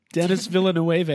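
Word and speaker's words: Denis Villeneuve: Dennis Villeneuve, I think. – Pretty good. That was pretty good.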